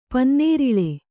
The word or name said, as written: ಪನ್ನೀರೆಲೆ